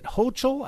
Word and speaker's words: Hochul: You have the New York governor, Kathy, is it Hochul? I think that's how she pronounces it, H-O-C-H-U-L.